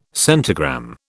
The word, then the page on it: centigram: British English